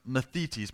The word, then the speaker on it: mathetes: Remember, I've said before in this series, the word disciple is translated from a word that's pronounced mathetes. Mathetes literally means learner.